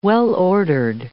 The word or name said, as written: well-ordered